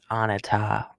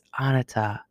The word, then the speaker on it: Anita: As a matter of fact, I keep hearing my name as Anita, which it's spelled the same way, A-N-I-T-A, but it's Anita.